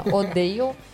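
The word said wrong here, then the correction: Odeo: ódio, odeio, enfim. – É O-D-E-O. – Odio.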